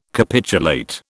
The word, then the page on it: capitulate: British English